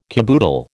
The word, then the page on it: caboodle: American English